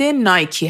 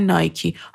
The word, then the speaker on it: Nike: املای برند نایکی همونطور که میدونید N-I-K-E هستش که از بعضی ها میشنویم بهش میگن نایک بعضی ها هم میگن نایکی حالا کدوم یکی از اینا درسته در اصل این تفاوت تلفظ ها به خاطر لحجه های مختلفه تو لحجه بریتیش و در اروپا و انگلیس شما این برند رو نایک میشنوید اما در امریکا بهش میگن نایکی